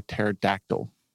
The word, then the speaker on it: pterodactyl: Why can't you hear a pterodactyl go to the bathroom? Because the P is silent.